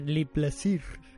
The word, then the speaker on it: Le Plaisir: es mi favorita y hizo El placer, El placer, Le placer.